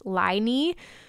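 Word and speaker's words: Lyny: So it's L-Y-N-Y, all caps, but it's pronounced Liney.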